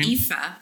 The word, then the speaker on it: Aoife: Aoife. I tell people it's like FIFA, the soccer organization, without the F.